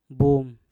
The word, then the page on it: bum: Mpur